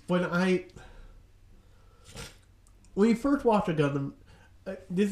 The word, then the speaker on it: Gundam: I don't wanna yuck your yum, but it's pronounced Gundam.